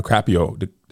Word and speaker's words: DiCaprio: And then Leonardo DiCaprio. DiCaprio, put the R emphasis on the wrong syllable.